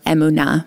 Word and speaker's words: emunah: Amunah, or emunah, more properly pronounced in Hebrew.